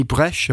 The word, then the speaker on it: Bresch: Yeah, and we're privileged to be joined by Benny Bresch.